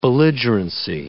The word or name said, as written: belligerency